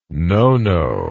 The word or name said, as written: no-no